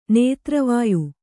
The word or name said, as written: ನೇತ್ರವಾಯು